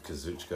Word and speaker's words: Kazuchika: Trying to pronounce the first name. – Yeah, no, it's... – Don't try Okada's. – Kazuchika, I think. – Yeah, that's exactly right. Kazuchika Okada.